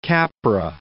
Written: Capra

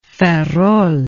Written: ferrol

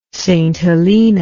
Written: St Helena

